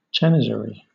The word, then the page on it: janissary: British English